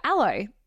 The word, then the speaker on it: Alo: But most of all, when I was away recently, I went for a shop in London and I came across that store, Allo. Is that how you say it? – Oh, like A-L-O.